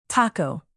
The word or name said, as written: taco